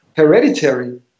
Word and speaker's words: Hereditary: Das heißt, du bleibst bei, ich muss jetzt, wie, sag nochmal bitte, wie sprichst du diesen Film aus, wo du gesagt hast, den findest du super, weil ich habe das noch nie aussprechen können. Hereditary. Hereditary, okay, aber ja.